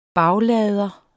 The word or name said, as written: baglader